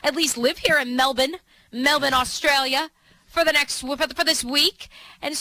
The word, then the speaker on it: Melbourne: Apparently, they don't pronounce the R. – Okay, so no R's. – No, Melbourne. Okay. Melbourne. You know how, but like you could still hear the.